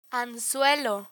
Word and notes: anzuelo: spanish

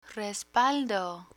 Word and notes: respaldo: spanish

